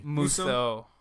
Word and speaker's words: Musou: Musho, or I can never pronounce it correctly. – Musou. – Musou.